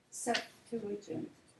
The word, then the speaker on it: Septuagint: Septuagint. – Is it I-N-T or A-N-T? Is it A-N-T? A-N-T or I-N-T? – S-E-P-T-U-A-G-I-N-T.